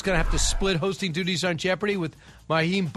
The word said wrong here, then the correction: Mayim: Bialik. Did I say that right? – I believe Mayim. – Yeah, Mayim Bialik.